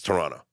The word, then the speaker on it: Toronto: You don't pronounce the second T. – Toronto? – It's Toronto. – Okay. – Yeah, that's how you say it.